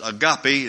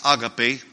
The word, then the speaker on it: agape: You would probably pronounce it agape. It's agape.